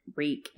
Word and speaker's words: Reek: Reek, Reek, it rhymes with weak.